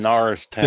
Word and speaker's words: Norristown: Good to be here from Norristown.